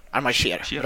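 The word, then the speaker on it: Aimar Sher: Det är så man uttalar det, jag har jättesvårt för hans efternamn. – Aymar Cher. – Bra.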